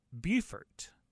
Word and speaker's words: Beaufort: And right after that comes Beaufort. Of course, it's oftentimes mixed up for people not in the Carolinas with the same spelled city in South Carolina, but the pronunciation's different. Beaufort.